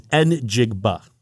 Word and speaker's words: Njigba: And it is Njigba. That's how you pronounce it.